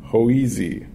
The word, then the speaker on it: Hoese: I want Cody Hoese, I think is how you say his last name. Hoese, H-O-E-S-E, a third baseman from Tulane.